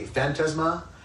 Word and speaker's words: phantasma: The Greek word that's used here in these two verses I read, by the way, is the word, we would pronounce it phantasma. In the Greek, the emphasis would be early on, be phantasma, which means appearance, an apparition, some sort of a specter.